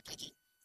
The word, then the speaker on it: call: El verbo to call, llamar, por teléfono o así en la calle, alguien, se pronuncia call y no call. Ok, call. – Call. – Eso es call. Es un sonido de vocal bastante largo.